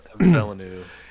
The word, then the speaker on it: Villeneuve: Villeneuve. – Yeah. – And Villeneuve.